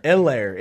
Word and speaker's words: Hilaire: Hilaire. It's Hilaire. Not, there's no, there's H is not pronounced.